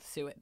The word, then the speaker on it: suet: We're going to talk about suet this week. – Can you I, spell it for everyone? – Yes. Suet is S U E T. Okay. And like, like I. Duet. Yes.